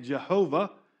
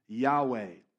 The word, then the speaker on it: Yahweh: But some think you say it Jehovah. But most modern scholars agree it was probably pronounced Yahweh.